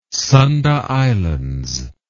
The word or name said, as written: Sunda Islands